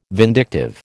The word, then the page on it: vindictive: American English